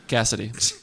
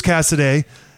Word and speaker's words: Cassidy: Is that the correct pronunciation for the coach's name? – It is. – It's not Cassidy. – Cassidy. – Bruce Cassidy.